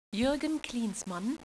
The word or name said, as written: Klinsi